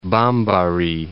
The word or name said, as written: Bambari